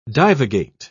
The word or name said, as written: divagate